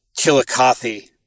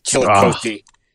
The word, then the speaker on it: Chillicothe: But it's pronounced Chillicothe, Ohio, not Chillicothe. Or however you upper west, northwest hippies say it. – But he's not picking on you. – Chillicothe. It should just roll off the tongue.